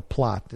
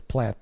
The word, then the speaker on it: plat: Okay, pronounce the A like an O, plot. It says plat, but plot, saith the Lord.